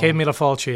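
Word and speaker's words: céad míle fáilte: What made you bring that Irish hospitality of the Cead Mila Fulcha, I believe. I probably said that wrong. – Cead Mila Fulcha, yeah. Very good.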